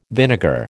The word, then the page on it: vinegar: American English